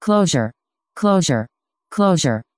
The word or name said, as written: clojure